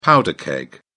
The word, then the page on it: powder keg: British English